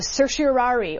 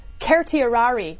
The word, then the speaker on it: certiorari: Will they issue a writ of certiorari, or the actual correct Latin pronunciation, I am told by Latin professors, is certiorari, which nobody in the history of lawyers has ever used in their life.